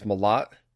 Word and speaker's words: Mellott: It's Mellott.